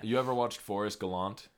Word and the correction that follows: Galante: Or Galante, I think is how you say his name.